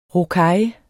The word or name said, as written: rocaille